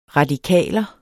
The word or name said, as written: radikaler